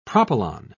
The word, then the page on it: propylon: British English